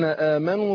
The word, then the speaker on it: آمنوا: لو إخواننا وأخواتنا المشاهدين والمشاهدات فاتحين معنا المصاحف، لو تبص على كلمة آمنوا، إنما المؤمنون الذين آمنوا بالله، بعد الواو ألف عليها صفر مستدير. الصفر المستدير دوت لا ينطق في الوقف ولا في الوصل.